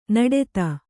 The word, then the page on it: ನಡೆತ: naḍeta; Kannada